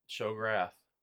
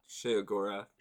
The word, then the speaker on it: Sheogorath: Uh, Shograth. What's his name? She- is it Sheogorath? Agora. – Oh, it's like I think it's pronounced Sheogorath. Sheogorath.